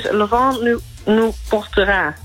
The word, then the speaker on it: Le Vent nous portera: Mijn Frans is misschien Franse uitspraak niet zo heel goed, maar het is Le Vent nous portera.